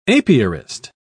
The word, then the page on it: apiarist: British English